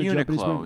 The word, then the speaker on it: Uniqlo: Uniqlo.